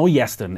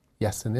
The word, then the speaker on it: Llestyn: Let us know, Yeston. It's spelled LLESTYN. – Yeston, yeah.